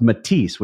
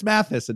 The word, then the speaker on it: Mathys: And that is, to your point about pronunciation, it's spelled M-A-T-H-Y-S, but it's pronounced Matisse, which I learned by watching the ad. Right. – You and yes. I would have been Chris Mathis until we Correct.